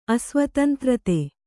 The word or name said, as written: ಅಸ್ವತಂತ್ರತೆ